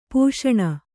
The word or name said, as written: ಪೋಷಣ